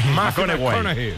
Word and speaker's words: McConaughey: McConaughey.